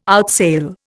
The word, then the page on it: outsail: Indian